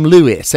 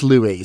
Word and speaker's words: Louis: Yesterday, we talked about little Louis, who I know I mispronounced. His name is Louis. Sometimes I call him Louis, and it's not right. It is Louis.